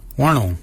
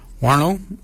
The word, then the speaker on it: wondil: So for shoulder, we say warnong. Warnong is your shoulder. Warnong is spelled W-O-N-D-I-L, warnong. Your nail is your underarm. Nail is spelled N-G-A-R-L-Y.